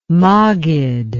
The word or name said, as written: maggid